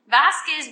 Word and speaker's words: Vazquez: Vasquez, V-A-Z-Q-U-E-Z.